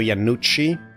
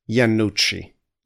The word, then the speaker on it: Iannucci: Today we're here to talk about The Death of Stalin from director Armando Iannucci.